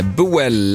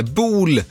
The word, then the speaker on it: Boel: Bakerman, Laidback och Hanne Boel, eller Bol. Nu är vi tillbaka på det där danska uttalet som jag ju blottade för er tidigare, att jag inte riktigt besitter. Laidback är ju nämligen en dansk musikgrupp bildad 1979 i Köpenhamn.